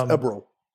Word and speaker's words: Eberl: It's Eberl.